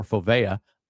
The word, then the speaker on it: fovea: Because of the fovea, F-O-V-E-A, or fovea, or fovea.